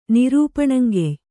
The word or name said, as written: ನಿರೂಪಣಂಗೆಯ್